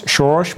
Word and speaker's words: Soros: Soros moet ik zeggen, ik moet het wel een beetje nu. Hmm. Nu ik in Hongarije woon, moet ik het wel op zijn Hongaars uitspreken.